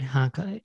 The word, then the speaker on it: Honkala: Yeah, Ingrid Honkala, H-O-N-K-A-L-A.